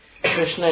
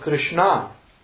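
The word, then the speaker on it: Kṛṣṇā: Of course, Western people say Krishna, Krishna. But Krishna is a feminine word, which means lady dark. – Krishna with a long A. – Krishna with a long A is the dark lady.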